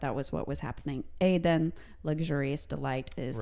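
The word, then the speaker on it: Aden (עדן): or as it's pronounced, Aden.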